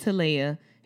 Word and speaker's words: Talia: I have to put that because people always say Talia and it drives me insane. But it's Talia. It rhymes with Himalaya.